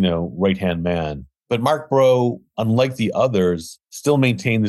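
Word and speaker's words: Breault: Mark, it's actually pronounced Bro.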